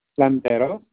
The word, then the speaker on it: Landeros: Landeros, that is spelled L-A-N-D-E-R-O-S, and it's hyphenated with Garcia, G-A-R-C-I-A.